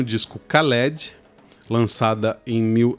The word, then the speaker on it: Khaled: Khaled é como a gente acaba falando aqui no Brasil, né? Lá na Argélia o pessoal pronuncia, aí mais uma vez atenção para minha pronúncia, Khaled. Khaled, alguma coisa assim.